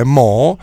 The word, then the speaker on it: Mu: in realtà è Mo, anche se si dovrebbe leggere, uno gli verrebbe naturale leggere Mu perché è M-U, ma la pronuncia esatta è Mo,